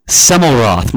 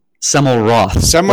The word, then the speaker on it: Semmelroth: Roth. Semmelroth. Like a Roth IRA. – Oh, R-O-T-H, okay, T-R-O-T-H, but the L-T's are silent, right? – It might have been misspelled.